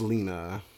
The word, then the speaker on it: Lena: And so my worst dress of the night is Leon Waithe. – Oh no, you did not. – What? – Leon. – Leon. – It's Lena.